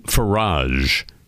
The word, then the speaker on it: Farage: There's a very popular, a notorious member of parliament, Nigel Farage, F-A-R-A-G-E, rhymes with Keldomage.